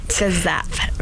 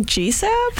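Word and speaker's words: GSAPP: I never know how to pronounce that. – Gazap, right. – So Gazap. – Right. – Got it. I'm looking at that, I'm like, GSAP? – I know.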